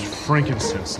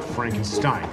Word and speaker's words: frankincense: Okay, just remember that it's pronounced frankincense, not Frankenstein, okay? – Okay.